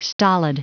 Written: stolid

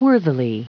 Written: worthily

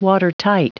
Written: watertight

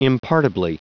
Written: impartibly

